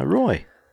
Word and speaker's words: Roi: But Roy? – R-O-I? – Yeah. Yeah, Roy.